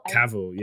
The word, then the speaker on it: Cavill: They've rebooted it with how do you pronounce this dude's last name? Henry Cavill? Cavill? – Cavill, Cavill. – Okay, I've been saying it right then.